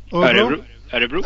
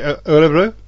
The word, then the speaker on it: Örebro: Örebro. – Thank you. Örebro.